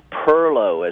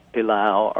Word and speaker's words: perlo: Perlo, as we say. Perlo is, most people would say pilaf or pilo or pilau. Or there's many pronunciations in Charleston. You also hear perlo, but it's a bean and rice dish seasoned with smoked pork and enlivened with hot peppers.